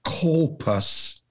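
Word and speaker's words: kolpos (κόλπος): In the Greek, bosom is pronounced kolpos, which means it's more than just a part of a human anatomy.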